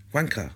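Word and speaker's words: Juanca: Okay, and my son speaks Spanish fluently, so I said, how do I pronounce his name? And he said, well, it's pronounced... Wanker.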